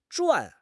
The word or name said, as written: zhuan4